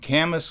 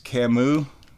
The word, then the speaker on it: Camas: From Camas, Washington. That's not pronounced Camus.